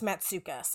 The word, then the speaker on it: Matsoukas: So you can tell that Melina, and I'm pretty sure it's Matsoukas. I looked it up and that's what the pronunciation was given to me.